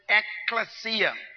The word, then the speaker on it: ekklesia: It's the word ekklesia.